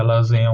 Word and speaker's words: Loseyns: Que apesar de acreditar que a lasanha é um prato tipicamente italiano, existe uma evidência de um prato muito similar, conhecido como lasan, se escreve L-O-S-E-Y-N-S, mas uhum.